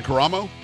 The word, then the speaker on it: Caramo: Is it Caramo? – Caramo, yes.